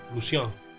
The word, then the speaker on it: Lucien: Allora, Lucien, che forse, forse, così si legge, tipo Lucien. Esatto, Lucien, è francese.